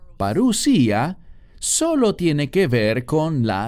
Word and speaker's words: parusía: Algunos la pronuncian parusia, pero es parusía.